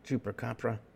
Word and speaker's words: Chupacabra: Spanish pronunciation is Chupacabra, some chupra to suck, and capra, goat, literally the goat sucker.